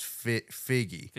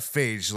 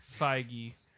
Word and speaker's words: Feige: and I was informed that the pronunciation of his last name is Figgy. – Figgy. – Figgy. Figgy. Figgy. Something like that. But it's not Phage, like I said. – Feige. – I think it's Feige.